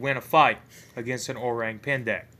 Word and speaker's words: Orang Pendek: In Sumatra, they have I really hope I pronounced this correctly, the Orang Pendek. – That's how you pronounce it.